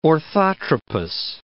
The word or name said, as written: orthotropous